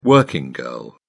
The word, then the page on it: working girl: British English